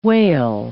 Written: wale

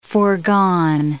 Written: forgone

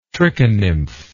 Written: trichonymph

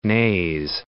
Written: Naze, The

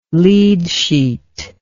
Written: lead sheet

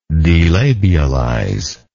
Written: delabialize

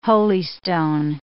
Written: holystone

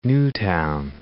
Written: Newtown

